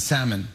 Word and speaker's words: salmon: She says, my date pronounced the L in salmon.